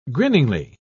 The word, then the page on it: grinningly: British English